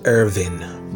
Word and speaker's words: Erwin: The man pronounced it Irvin, but it's spelled Irwin, E-R-W-I-N.